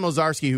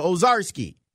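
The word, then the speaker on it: Owczarski: It's like, oh, and then czar, like a czar in there. And then the ski, so Ozarski.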